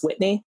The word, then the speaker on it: Whitney: And I find that sometimes when I say, oh, my name is Whitney and not Wendy, they'll be like, oh, okay, okay.